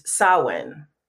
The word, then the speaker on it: Samhain: So for me, it makes sense to use the Celtic origins, and the way they pronounce it is Samhain. – Mm-hmm. So Irish Gaelic says Samhain.